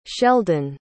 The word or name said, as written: shelden